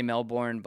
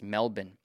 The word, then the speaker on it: Melbourne: I used to say Melbourne, but then everyone kind of told me that's not the way to say it. That's not the way the locals say it. So it's Melbourne.